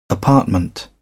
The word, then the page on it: apartment: British English